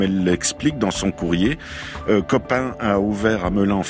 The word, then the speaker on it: COP IN: Elle, entre autres, parle de COP IN ou COP 1, comme on veut. Ça s'écrit COP plus loin, IN, I-N. Mais on aime bien dire COP 1 à Melun.